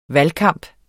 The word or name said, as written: valgkamp